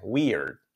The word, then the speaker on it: weird: Esta palabra, raro, extraño, weird, pronunciado al revés. Weird, weird. Trabajadlo, weird.